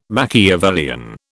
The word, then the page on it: machiavellian: British English